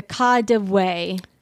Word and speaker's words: KaDeWe: Okay, so I'm going to I don't know exactly how you say it, but it's the Kadewe. It's Kadewe, which is an abbreviation for Kaufhaus des Westens or Department Store of the West.